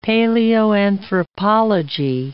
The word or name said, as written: paleoanthropology